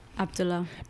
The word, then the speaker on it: Abdullah: Abdullah.